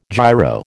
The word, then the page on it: giro: American English